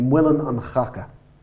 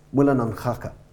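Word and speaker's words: Muileann an Chaca: Now, if we were to pronounce the mill of shit in modern Leinster Irish, modern East Leinster Irish, like people in Dublin speak, it would be Mullin an Chaca.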